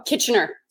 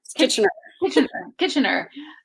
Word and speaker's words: Kitchener: Oh, Kitchener. – Kitchener. Oh, okay. Yeah, it kind of looks like kitchen and then the er at the end, I guess.